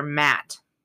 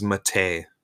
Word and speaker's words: matte: They're matte. – Yeah. – It's the lack of shininess. – I always thought Matt was Mate. It's one of those words I've seen, but always mispronounced in my head.